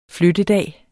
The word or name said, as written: flyttedag